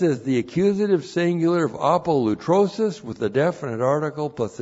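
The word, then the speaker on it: apolutrosis (ἀπολύτρωσις): Now, apolutrosis, looks like that in the Greek, that's A-P-O-L-U-T-R, long O-S-I-S, apolutrosis.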